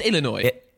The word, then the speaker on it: Illinois: Just Illinois. – Illinois. There we go, Illinois.